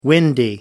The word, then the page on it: windy: American English